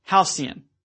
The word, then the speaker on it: halcyon: So note the second syllable sea in the word halcyon.